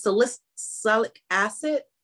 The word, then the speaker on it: salicylic acid: Is it salicylic acid? How do you pronounce it? Like Salic- salicylic acid? Yes.